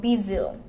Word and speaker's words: Bisous: It's because, like, okay, so Bisous. That's how you pronounce it.